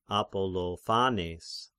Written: Apollophanes

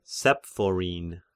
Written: Saphoth

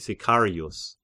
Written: Sicarius

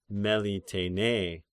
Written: Melitene

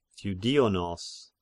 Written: Theudinos

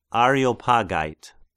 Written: Areopagite

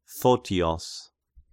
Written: Photios

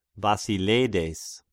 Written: Basileides

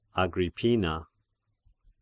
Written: Agrippina

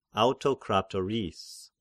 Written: Autokratoris

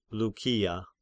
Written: Lykia

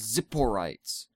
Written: Tzipporites

